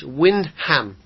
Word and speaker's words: Wyndham: There's a silent H, Windham.